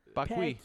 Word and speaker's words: Paqui: It's P-A-Q-U-I. Pocky. Pocky.